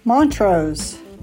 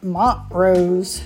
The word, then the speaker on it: Montrose: Montrose, not Montrose.